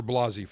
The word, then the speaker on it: Blasey: Oh, I was calling her Blakey Ford. I'm sorry. Dr. Blasey Ford. I hope I don't get summoned from some committee saying that I was purposely mispronouncing her name. Senator Blasey, or correction, Dr. Christine Blasey Ford. I want to make sure that I correct that. So,